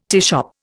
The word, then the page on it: teashop: Indian